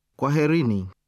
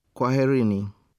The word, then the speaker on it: Kwaherini: And to two or more people, Kwaherini. – Listen again to the pronunciations. – Karibu. Karibuni. – Asante. Asanteni. – Kwaheri. Kwaherini.